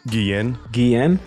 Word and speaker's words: Guillen: Guillen.